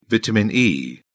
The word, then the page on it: vitamin e: British English